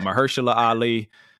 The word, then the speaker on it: Mahershala Ali: And also interesting, and I always butcher his name, and Dane gonna correct me, but Mahershala Ali, basically... – Ah, give yourself a round of applause.